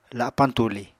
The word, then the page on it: lapañ tuli: apatani